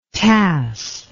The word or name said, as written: tass